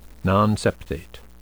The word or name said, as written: nonseptate